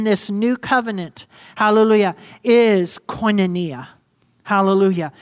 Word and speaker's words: koinonia: And koinonia. Everyone say koinonia again. Look at a neighbor and say koinonia. Let's spell it. Go ahead. K-O-I-N-O-N-I-A. Koinonia.